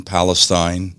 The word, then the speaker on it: Palestine: who is a resident in Palestine, that's how they pronounce it, Ohio.